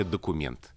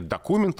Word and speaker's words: документ: И поэтому русский язык выбрал ударение «документ». А ударение «документ» ушло в просторечие.